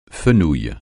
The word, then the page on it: fenouil: francais